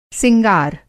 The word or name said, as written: सिंगार